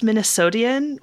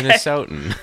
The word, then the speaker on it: Minnesotan: Do you call yourselves Minnesotian? What is the Midwest? – No, absolutely not. Okay. Minnesotan. – Minnesotan.